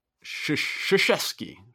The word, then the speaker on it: Krzyzewski: So speaking of a move that also makes sense, Coach K, I'm not even trying to pronounce his last name. – Krzyzewski. – K-S. – I think it's K-R.